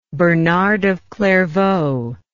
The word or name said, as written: Bernard of Clairvaux